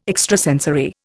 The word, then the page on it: extrasensory: Indian